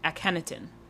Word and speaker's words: Akhenaton: His name is, oh God, I'm gonna mess this up, but Akentiton Jones. It's spelled Akhenaton. Akhenaton. Okay.